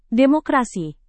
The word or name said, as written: demokrasi